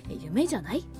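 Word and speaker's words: 夢?: はてなの曲で夢クエスチョンマークと書いて夢じゃないと読むそうなんですけれども、この曲はどんなところが好きなんですか。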